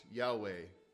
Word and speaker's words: Yahweh: And in the original, in the ancient Hebrew, I understand there were no vowels, so it's not possible for us to be certain exactly how this name for God was originally pronounced. Most people who study Hebrew would say it would have been pronounced Yahweh, but that's not certain.